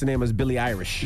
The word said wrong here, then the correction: Billie Eilish: Yes. – I didn't know. But my daughter, she corrected me. Dad is yes. Billie Eilish, but I didn't know.